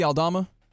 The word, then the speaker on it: Aldama: Aldma, is that how you say it? – Santi Aldama.